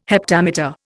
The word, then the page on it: heptameter: Indian